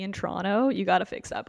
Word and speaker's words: Toronto: Toronto. No second T.